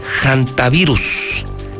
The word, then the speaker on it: hantavirus: me dice que la pronunciación correcta de este nuevo virus es hantavirus. Hantavirus, escribe con H, hantavirus, y la pronunciación, gracias doctor Cheo, es hantavirus.